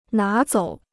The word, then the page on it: 拿走: ná zǒu